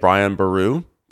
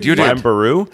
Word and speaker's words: Brian Boru: I played Brian Boru. Did I say it correctly that time? – You did. – Brian Boru.